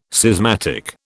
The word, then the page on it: schismatic: British English